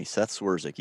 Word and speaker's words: Seth Swerczek: Seth Swerczek, you got it.